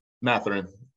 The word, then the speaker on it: Mathurin: Mathurin of Indiana. What do you think of Mathurin? Am I saying that right? – Mathurin.